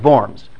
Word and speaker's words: Worms: And it's not pronounced the diet of worms. It's pronounced the diet of worms. Worms is a city in the Rhineland in Germany, and a diet is an imperial court, an imperial assembly.